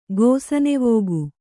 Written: ಗೋಸನೆವೋಗು

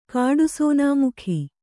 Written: ಕಾಡುಸೋನಾಮುಖಿ

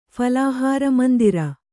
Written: ಫಲಾಹಾರಮಂದಿರ

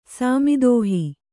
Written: ಸಾಮಿದೋಹಿ

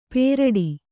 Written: ಪೇರಡಿ

